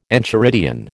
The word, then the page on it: enchiridion: American English